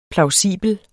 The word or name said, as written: plausibel